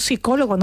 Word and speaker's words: psicólogo: La palabra psicólogo se escribe con una P inicial, pero esa P no la pronunciamos. – Ah, es verdad. – Decimos psicólogo, no decimos psicólogo. No digan ustedes esa P inicial, que eso no se dice, eso es muy cursi. Psiquiatra, psicólogo, psicotécnico, pseudociencia, una palabra tristemente tan usada ahora. Palabras que escribimos con PS, pero pronunciamos con S.